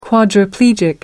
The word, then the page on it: quadriplegic: American English